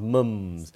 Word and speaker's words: mum: By the way, this book is called Where the Wild Mums. – Mums! – That's a very non-US pronunciation. It's usually Australian or British. They'll say mum.